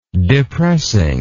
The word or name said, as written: depressing